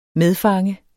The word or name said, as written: medfange